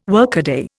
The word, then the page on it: workaday: Indian